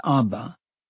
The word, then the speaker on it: Abba: pronounced as Abba.